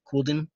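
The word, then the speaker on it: Couladin: And I can see actually by 1.5 seasons, and by the end of season 2, you see Matt kill Caladan. Caladan? How do you say it? Cooldane? Cooldan? – Cooldan.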